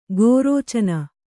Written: ಗೋರೋಚನ

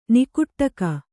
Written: ನಿಕುಟ್ಟಕ

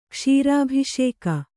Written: ಕ್ಷೀರಾಭಿಷೇಕ

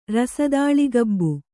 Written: ರಸದಾಳೆಕಬ್ಬು

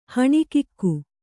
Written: ಹೊಣಕಿಗ

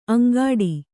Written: ಅಂಗಾಡಿ

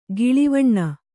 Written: ಗಿಳಿವಣ್ಣ

